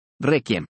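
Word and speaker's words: réquiem: Kid y requiem, ha adaptado su pronunciación a kid, requiem, esto es, sin pronunciar la U.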